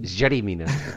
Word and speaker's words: Yerry: I say Jetty because we put a J on a LL or a Y. – God, language is so confusing. – Jetty Mina.